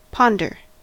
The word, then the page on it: ponder: American English